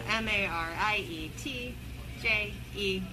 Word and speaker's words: Marietje: Uh, M A R I E T J E H A U P R I C H.